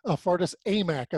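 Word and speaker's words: Fardaws Aimaq: And Fardas Amak, I want to make sure I'm pronouncing that right, he pulled down 18 rebounds in the victory too. It's like that whole team is a highlight reel. – They are. Fardas Amak, I believe, is how he pronounces it.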